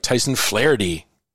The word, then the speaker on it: Flaharty: Did I get it right? Tyson Flaharty. – Oh, yeah, you nailed it. – Right on. Okay. Because it isn't, it's when you look at the spelling, it's like, yeah, I could get Flaharty out of there, but. Gaelic. – Yeah, well, a lot of people misspell it and that throws them off. But no, you got it.